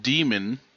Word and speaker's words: Daemon: This week I've been listening to an oldie but a goodie called Demon by Daniel Suarez. It's spelled D-A-E-M-O-N, because it's supposed to be like the Unix demon.